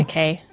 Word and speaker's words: Takei: Takei.